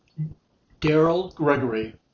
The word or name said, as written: Daryl Gregory